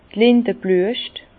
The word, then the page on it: d' Lindeblüescht: Bas Rhin; Herrlisheim